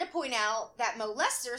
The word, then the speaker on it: Leicester: The initials of the name Mo, i.e., if they're let's say it's Matthew Owen, and her surname is Lice, is L E I C E S T E R, but it's pronounced Lester.